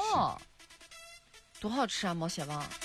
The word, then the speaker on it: 毛血旺: 好，还有一个问题，毛血旺大家都吃过吧？ – 吃过啊。 – 对，应该叫毛血旺，对，叫这个发音叫毛血旺，对。